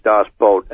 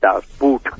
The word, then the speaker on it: Das Boot: There are many submarine movies, but there's only one Das Boot, and you pronounced it correctly. It's not Das Boot. It's not a piece of footwear. – Yeah. – To a German, B-O-O-T is boat, and it is an incredible, and it's based on a true story.